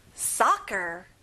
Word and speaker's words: soccer: 축구, soccer. So를 좀 강하게 말해보세요.